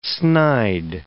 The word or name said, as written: snide